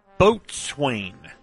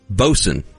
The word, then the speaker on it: boatswain: Boatswain. – That's not how that word is pronounced. – How is it? – Bosun.